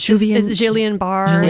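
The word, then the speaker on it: Guillain-Barré: Guillain-Barre syndrome. – Yeah. – Say it again. – Yeah. – Which is Guillain-Barre. – Guillain-Barre.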